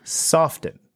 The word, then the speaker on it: soften: And if there aren't as many young people raising families as Ben and Ivy Zelman predict, demand for single-family homes should soften. Do you say? Do you pronounce the T in that word? Soften. Soften.